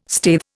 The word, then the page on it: staithe: Indian